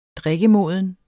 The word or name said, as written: drikkemoden